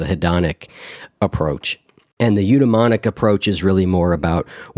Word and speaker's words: eudaimonic: Eudaimonic? – Sure. – Okay, eudaimonic.